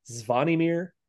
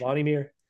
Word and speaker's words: Zvonimir: So it's Zvonimir Ivishich. – Ivishich? – Zvonimir Ivishich.